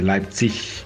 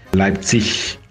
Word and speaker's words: Leipzig: que se pronuncia correctamente Leipzig, Leipzig, todo con